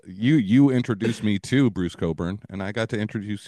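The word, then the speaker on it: Cockburn: Who's gonna read that and say, oh, it's clearly Coburn? – Exactly.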